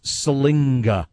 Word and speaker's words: Siliga: It's spelled S-I-L-I-G-A, like Saliga or Saliga. It's Salinga. There is an N in there in the pronunciation we have been told to use.